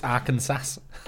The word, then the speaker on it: Arkansas: It's Arkansas. – Oh, Arkansas. Yes, okay. – Yeah, okay. – So back in the day, in the mid to late 1800s in Arkansas, they rented criminals to work on big farms.